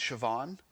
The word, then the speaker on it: Siobhan: I guess her first name is pronounced Siobhan, which I never knew.